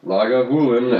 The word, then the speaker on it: Lagavulin: In 1816, local farmer and distiller John Johnston, good God, founded the first legal distillery here at Lagavulin, pronounced Lagavulin after the Gaelic Lagan Moolian or something, which means the hollow where the mill is.